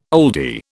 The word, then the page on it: oldie: British English